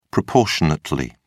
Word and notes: proportionately: British English

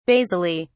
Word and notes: basally: British English

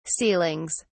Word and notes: ceilings: British English

